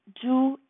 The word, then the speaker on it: Zhu: It is pronounced Zhu in Mandarin Chinese, and it means the color red.